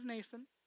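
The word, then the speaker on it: Mnason: He receives a warm welcome at the home of a believer named Nason. Silent M, threw me off too. His name is Nason, Acts 21:17.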